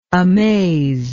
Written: amazedness

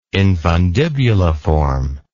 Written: infundibuliform

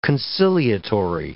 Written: conciliatory

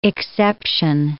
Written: exception

